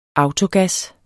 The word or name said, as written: autogas